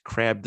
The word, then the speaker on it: Krabbe: But as far as, trying to help out crab disease, they call it, I believe that's the pronunciation of it. K-R-A-B-B-E.